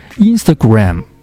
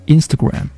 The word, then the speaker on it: Instagram: 再比如著名的 Ins、它的发音不是 Instagram 而是 Instagram，重音出现在第一个音节。